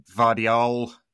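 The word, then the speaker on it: Gvardiol: And they've added Vardiol, I think that's how you say it. It's a silent G, who is an excellent defender.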